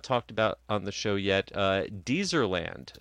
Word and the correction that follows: Deserland: Deserland. Thank you. Thank you for giving me the correct pronunciation on that. – Just think of a desert, because it was like a desert before Deserland.